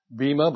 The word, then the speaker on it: Bema: It's spelled in English letters, B-E-M-A. We hear it Bema, but it's probably Bema.